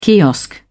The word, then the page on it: kiosk: British English